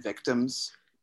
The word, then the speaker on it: VCTMS: I remember I had this band Victims, but it's spelled VCTMS.